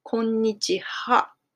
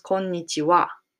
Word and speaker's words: konnichiwa: But konnichiwa, when you write it down, if you know hiragana, we write it down ha, konnichiha, as hiragana. But we pronounce konnichiwa. And the reason why it's happened is because wa is a particle.